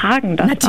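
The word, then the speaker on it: Häagen-Dazs: Hallo, ich bin Hagen das und unsere Eiscreme-Marke spricht man Hagen das aus, ohne den Umlaut, der wird im Skandinavischen nicht mitgesprochen.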